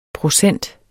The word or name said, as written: procent